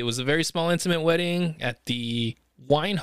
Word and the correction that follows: Wynn: Hotel. I could be pronouncing that wrong. W-Y-N-N, is that correct, Donald? You're in Vegas, guy. Is that Wine? – The Wynn Hotel? – Wynn, there you go.